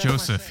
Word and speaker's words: Jeaux Seph: That was Joseph here on the scene from Indiana Public Radio. Now, the spelling of his name is kind of different. – I spell it like a French ass.